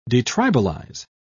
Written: detribalize

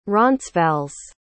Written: roncesvalles

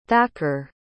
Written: thacker

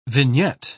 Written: vignette

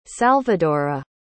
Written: salvadora

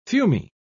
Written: fumy

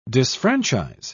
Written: disfranchise

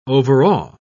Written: overawe

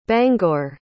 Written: bangor

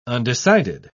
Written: undecided

